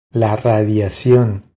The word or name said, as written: La radiacion